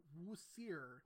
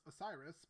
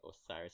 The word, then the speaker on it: Osiris: which I thought the name was always pronounced Osiris, but I guess it's pronounced Wu Sir. – No, it is definitely Osiris, so that's the English translation of the word.